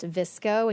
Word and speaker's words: VSCO: So Visco, which is spelled V S C O. It's pronounced Visco.